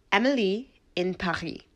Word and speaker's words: Emily in Paris: And that is Emily in Paris. No, I'm not being an asshole. No, I didn't just want to flaunt my French accent. Netflix Twitter account has officially confirmed that that is the pronunciation of the show. It's a play on, well, it's not a play on, but it's meant to rhyme, and we just all didn't get it.